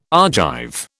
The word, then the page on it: Argive: British English